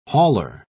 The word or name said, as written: hauler